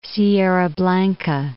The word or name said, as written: Sierra Blanca Peak